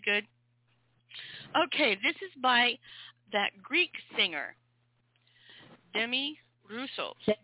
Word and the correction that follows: Demis: Demis. – Oh, you pronounce the S in the Greek?